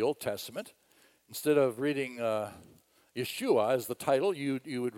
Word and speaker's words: Yeshua: Yeshua, if you say his name in Hebrew, that's how you say it, Yeshua in Hebrew.